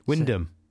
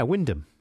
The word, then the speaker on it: Wymondham: Wyndham.